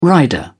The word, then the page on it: rider: British English